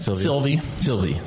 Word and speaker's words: Sylvie: Too much Sylvia? Is that right? Am I saying that right? – Sylvie. – Sorry.